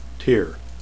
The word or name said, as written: tear